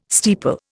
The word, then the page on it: steeple: Indian